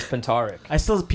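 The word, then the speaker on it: Puntarić: Well, in the native Croatian, Puntarik. It is Puntarik.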